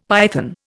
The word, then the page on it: python: Indian